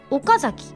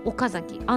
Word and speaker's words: 岡崎: 一般的には前側を強く発音していますが今ねすごくそこのところが太文字になっていたのであの「岡」と「あん」と「ち」と「に」がねわざと岡崎安城知立西尾って読んだ見たんですが地元では平坦に読んでいるんです。